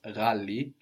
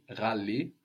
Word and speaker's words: ghali: So this GH sound is pronounced like with a little throat friction, ra, but it's not very common in Swahili. Mostly it's used for foreign words like ghali, which is Arabic.